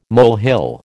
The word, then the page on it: molehill: American English